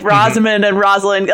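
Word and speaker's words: Rosalynn: Apparently she is, I don't want to say insistent because it's her own name, but like clear that her name is pronounced Rosalind. – Oh, Rosalind. Okay.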